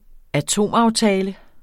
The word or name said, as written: atomaftale